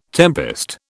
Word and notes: tempest: British English

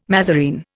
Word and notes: mazarine: Indian

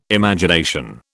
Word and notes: Imagination: British English

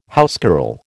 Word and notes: housecarl: American English

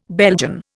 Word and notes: Belgian: Indian

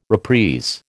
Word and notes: reprise: American English

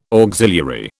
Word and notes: auxiliary: British English

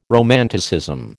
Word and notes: romanticism: American English

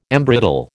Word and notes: embrittle: American English

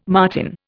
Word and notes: marten: Indian

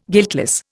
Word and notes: guiltless: Indian

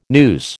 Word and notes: noose: American English

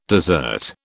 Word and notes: dessert: British English

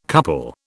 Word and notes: couple: British English

